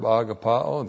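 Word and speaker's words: agapao (ἀγαπάω): You know this is his word, the verb agapao, the noun agape. A-G-A-P-A, long O, the verb for virtue, love.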